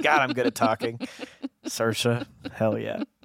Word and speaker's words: Saoirse: She said, we're watching a movie and she said, is that Cersei Roman's husband? – Damn. – And I was like, well, first of all, it's Saoirse Ronan, but also, can you blame her? – I mean, no.